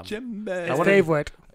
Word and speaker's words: djembe: I wanted to make sure that I got that word right because it's spelled with a D. – Yeah. I'm like, I had no idea. I was thinking a twice. – It's D-J-E-M-B-E. That's fucking weird. Djembe. – Just like gin.